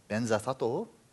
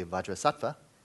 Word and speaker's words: Vajrasattva: But I mention Benzathato, the Tibetan pronunciation, came for not being able to say Vajrasattva. It's true, you can't say Vajrasattva in Tibetan. They don't have a va sound, they don't have jra sound, and they don't have a tva sound. So that's three syllables out of three syllables. You don't, that's kind of a low batting average.